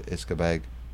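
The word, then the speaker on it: uisce beatha: The original pronunciation was Iscabeg, which means the water of life.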